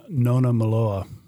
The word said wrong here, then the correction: Nona Malou: Malou. Nona Malou, I believe, is how you say that.